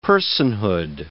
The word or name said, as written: personhood